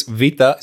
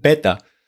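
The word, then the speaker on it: beta (βήτα): And here comes a big question, because some people say that in ancient Greek, people really said beta. Like in ancient Greek, this vita is pronounced beta.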